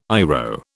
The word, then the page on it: IRO: British English